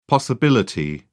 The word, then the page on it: possibility: British English